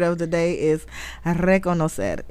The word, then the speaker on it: reconocer: You got to roll your R's with this one, okay? So, the word of the day is reconocer. – Reconocer.